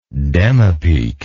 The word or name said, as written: demipique